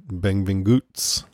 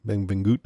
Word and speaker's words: Benvinguts: I went online and got a pronunciation. They said Benvinguts, like that. Benvinguts.